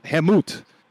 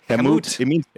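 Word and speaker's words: Kemet: So going back to ancient Egyptian magic, the kingdom was called Hemut. It's spelled with a K, K-E-M-E-T. – Hemut.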